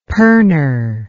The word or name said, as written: pernor